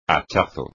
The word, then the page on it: hachazo: espagnol